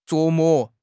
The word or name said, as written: 琢磨